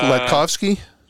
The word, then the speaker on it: Latkovski: Latkovski? – Yeah, it's pretty good. It's pretty much, it seems like intimidating when you see it, but it's pretty much how it's spelled, just Latkovski.